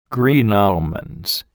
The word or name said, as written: green almonds